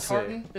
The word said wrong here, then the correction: Tarkatan: Tarkatan, that was right. I knew it was close. I was missing a syllable in there.